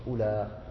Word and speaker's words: أولى: وأما الذين يقرؤون بالقصر أولى فهم تميم قيس ربيعة أسد همدان لا تنطق أولى بمد إنما تنطق بالقصر أولى وأهل حجاز أولئي.